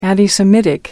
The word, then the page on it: anti semitic: American English